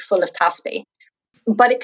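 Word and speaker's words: Teaspaí: So anyway, so like the TLDR is that I've set up an Irish games company, which is called Taspi Press. So it's spelled T-E-A-S-P-A-I, father, with like an accent.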